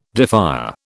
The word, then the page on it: defier: British English